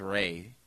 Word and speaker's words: Ralph Fiennes: He says it's pronounced Ray. – It's Ralph. – It's Ralph. – It's Ralph Fiennes. – It's Ralph Fiennes, but he says it's Ray.